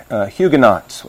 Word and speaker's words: Huguenots: The Christians in France, the Reformed Christians in France, were called Huguenots, or in the anglicized pronunciation, Huguenots, which is how we often pronounce it.